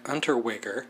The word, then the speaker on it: Unterweger: Another serial killer, Austrian, Jack Unterweger. Good job. – I do know how to pronounce his name because I listen to true crime oh, podcast.